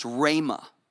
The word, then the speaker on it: rhema: There's another word that's translated word called, it's pronounced rhema. Everybody say rhema.